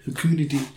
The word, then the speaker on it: fecundity: I need to draw a lot of uh, like a lot of goblins and or vic- uh, vicinity, fecundity. – Fecundity. – Fecundity. I don't actually know how to pronounce that at all. It is V. That's F. Fecundity.